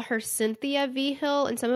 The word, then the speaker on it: Vigil: It's spelled V-I-G-I-L, but it's pronounced V. Hill.